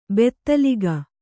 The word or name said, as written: ಬೈತಲಿಕ್ಕು